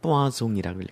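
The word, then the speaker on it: poisson: 포이즌은 뜻이 여기에서는 독이지만 프랑스에서는 샘물이에요. 알아두세요. 프랑스에서는 이거를 뽀아송이라고 읽어요.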